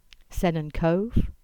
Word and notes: Sennen Cove: English

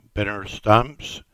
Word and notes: Binner Stamps: English

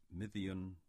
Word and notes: Mithian: English

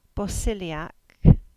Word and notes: Bosiliack: English

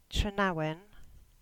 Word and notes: Trenawin: English

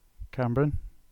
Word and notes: Camborne: English

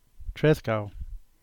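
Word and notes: Trescowe: Cornish

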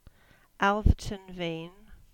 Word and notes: Alverton Vean: English

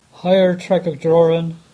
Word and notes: Higher Tregajorran: English

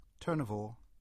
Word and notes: Turnavore: English

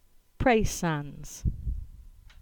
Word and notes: Praa Sands: English

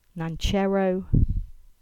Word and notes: Nancherrow: English

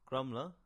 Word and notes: Grumbla: English

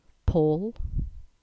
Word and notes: Paul: English

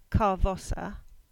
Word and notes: Carvossa: English

